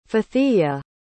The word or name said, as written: fathiyya